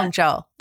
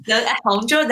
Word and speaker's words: Hangzhou: I've heard of Hangzhou. Yeah, yeah. Hangzhou. – Yes, yes. – Excuse my pronunciation now. – Standard Mandarin. It is. Hangzhou, that's, you said it in a quite good way.